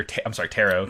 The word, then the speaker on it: tarot: He starts, like, doing tarot, or tarot. – Motherfucker. – How do you pronounce it? – Tarot. – Okay, I said it right a second. Silent.